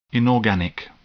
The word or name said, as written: inorganic